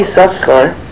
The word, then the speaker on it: Yisachar: The Bnei Yisachar, it's pronounced Yisachar, although Yisachar, but we say Yisachar.